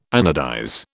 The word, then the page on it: anodize: British English